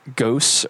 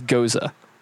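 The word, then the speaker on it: Gose: I actually looked up how to pronounce that, and it's not Gos or Goss. It's Goza.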